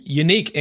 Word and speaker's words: Yannick: The first being that I never knew Yannick Ngakoue pronounced his first name Unique Ngakoue. That is the official pronunciation from what I understand.